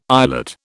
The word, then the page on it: eyelet: British English